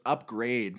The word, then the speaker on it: Upgrayedd: So it's Upgrade, but it's not... It's spelled like UPGRAYEDD. – Double D.